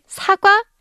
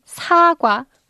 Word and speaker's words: 사과: And if you say 사과, it means apple. But if you say 사과, pronouncing 사 a little longer.